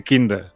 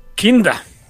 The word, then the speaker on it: Kinder: Ich hasse Kinder. – Ich hasse, Dios mío. Ich hasse Kinder. – Kinder. Kinder es niños. – ¿Pero cómo se pronuncia Kinder? ¿Kinde? – Kinda.